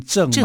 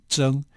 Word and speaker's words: 正: 这个字不是念正吗？ – 正嘛，大中至正。 – 对，可是我们破音字应该要念成征啊。